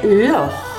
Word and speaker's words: Loch: En Escocia, el lago Lake no se dice Lake, no se pronuncia Lake, se dice Loch.